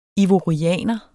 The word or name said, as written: ivorianer